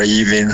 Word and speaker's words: even: Captain Cook, I cannot believe we are even having this discussion. – Even 结尾的 n 注意舌尖要顶上颚，你现在是一个前鼻音的发音。